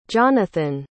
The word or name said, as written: johnathon